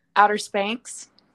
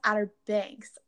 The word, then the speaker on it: Outer Banks: Outer Spanx. – Oh, I think it's pronounced Outer Banks. You might have heard Banks.